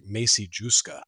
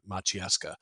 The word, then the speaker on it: Maciejewska: As far as the name, if you do want to Google pictures of Anna, phonetically, it looks like Macy Juska. MacieJewska. So it phonetically it looks like Macy Justa, but it's pronounced Maciejewska.